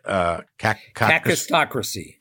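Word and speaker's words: kakistocracy: Cacistocracy.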